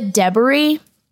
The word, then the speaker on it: Edebiri: I'm going to say one thing that is so, it's a Edebiri.